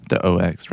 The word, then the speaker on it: OX: The OX, right? – Mm-hmm. – Okay. – The Aux. – Well, I don't know how you say it. I was just making sure we weren't saying AUX.